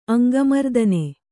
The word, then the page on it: ಅಂಗಮರ್ದನೆ: Kannada